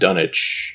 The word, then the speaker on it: Dunwich: I read that it should be pronounced Dunwich, you know, like Greenwich Village, is the villagers, if you will, are, I guess, supposed to be country Christian folk.